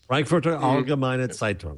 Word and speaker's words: Frankfurter Allgemeine Zeitung: And he's writing an op-ed piece in the Frankfurter Allgemeine Zeitung. Did I say that right? – Frankfurter Allgemeine Zeitung.